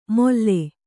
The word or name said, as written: ಮೊಲ್ಲೆ